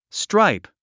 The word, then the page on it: stripe: stráip; ストライプ